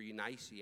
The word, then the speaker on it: Eunice: Grandmother, Lois, and in thy mother, Eunice, or Eunice, actually would be pronounced, and I am persuaded that is in thee also.